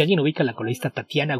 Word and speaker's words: Tatjana: Entonces es Tatiana, pero se escribe con J, se escribe Tatjana. – Sí, pero la J va antes de la T, si mal no recuerdo. – Según yo, no. – Ok, entonces es Tatjana. – Se escribe Tatjana, pero sí, Tatiana.